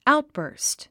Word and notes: outburst: áutbə̀ːrst; アウトバースト

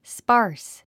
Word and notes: sparse: spάːrs; スパース

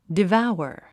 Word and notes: devour: diváuə r; デヴァウワ

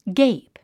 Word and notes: gape: ɡéip; ゲェイプ

